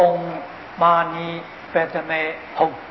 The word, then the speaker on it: Om Mani Padme Hum: 六字大明咒，这个我老师是藏传的喇嘛啊，张家大师啊，这个藏文的发音是 Om Mani Padme Hum 啊， Om Mani Padme Hum 啊， Om 这个字是什么意思呢？